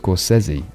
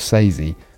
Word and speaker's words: Scorsese: A quick note here Scorsese is pronounced Scorsese, or so says he. – Hello, Vince. Hey, it's Marty Scorsese. – See? Us Brits tend to get from A to B by way of Scorsese.